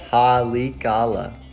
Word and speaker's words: Haleakala: Haleakala? – Oh, wow. Great. – Haleakala? Oh. How do you say it? – Let me see it. I can't. – It's Haleakala.